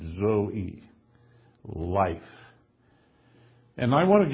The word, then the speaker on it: zoe: It's the word Zoe. That's the transliteration of the Greek word Z-O-E, long E, Zoe.